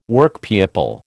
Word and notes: Workpeople: American English

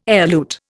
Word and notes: elute: Indian

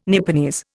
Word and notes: Nipponese: Indian

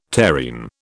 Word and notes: terrene: British English

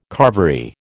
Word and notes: carvery: American English

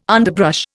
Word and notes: underbrush: Indian

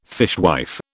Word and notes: fishwife: British English

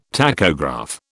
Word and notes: tachograph: British English

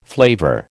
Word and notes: flavour: American English

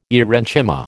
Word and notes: aerenchyma: American English

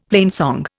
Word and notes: plainsong: Indian

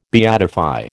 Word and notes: beatify: American English

